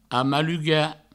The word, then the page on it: amalugar: amaluga